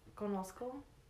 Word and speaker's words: conozco: like cono- um, conoce. How do you say it? Conozco. Yeah, like you like know someone, like, or you know something, and then you know someone.